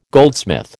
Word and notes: goldsmith: American English

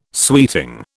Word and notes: sweeting: British English

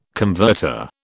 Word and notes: converter: British English

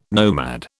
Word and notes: nomad: British English

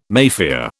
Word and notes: Mafia: British English

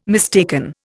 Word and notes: mistaken: Indian

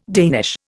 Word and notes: Danish: Indian

